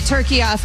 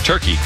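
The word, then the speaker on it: Türkiye: I will be saying it turkiya from now on. My entire lifetime, we have been incorrectly pronouncing turkey.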